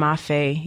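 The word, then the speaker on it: mafé: Is it mafe? How do you say it? – Oh, my gosh. The lamb mafe. Yes.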